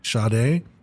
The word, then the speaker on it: Sade: Sade? – Yeah, it's spelled S-A-D-E. – Yeah, mm-hmm. She's got an invisible H. – Mm-hmm. See, exactly. – Yeah. Yeah, but it's there. You got to pronounce it.